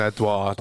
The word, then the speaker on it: Edouard: Yeah, that's how you say it, isn't it? Edouard? – Yeah, Odson Edouard.